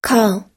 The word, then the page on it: call: American English